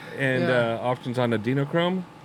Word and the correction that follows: adrenochrome: Am I saying it right? – Ooh, adrenochrome. Yeah, we can't talk about that, though.